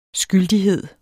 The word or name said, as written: skyldigheder